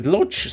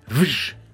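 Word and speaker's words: Łódź: Und Lodz heißt eben auf Polnisch gar nicht lutsch, sondern wisch.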